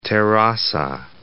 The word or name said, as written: Terrassa